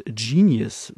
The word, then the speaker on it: Jeaniouss: Und das sind, waren auf der Bühne Fuzzle Brain und Genius, wird er, glaube ich, ausgesprochen. Also es wird so ein bisschen Jean, also so Jean, J-E-A-N-I-O-U-S. Ich glaube, er schreibt sich sogar mit Doppel-S, S-S. Ähm, Genius, Genius also ich glaube, Genius spricht man es aus.